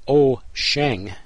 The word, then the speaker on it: Wouxun: Waukesan, pronounced O-Sheng, but spelled W-O-U-X-U-N, so that's why I'm pronouncing it Waukesan, but O-Sheng is a new name on the market.